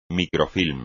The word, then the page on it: microfilm: Spanish